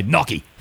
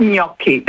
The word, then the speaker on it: gnocchi: Because I know a lot of Aussies just say gnocchi. – I know, and I cringe every time I hear. No, definitely it's gnocchi.